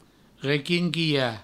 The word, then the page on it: requinquilhar: requinquiha; Provençal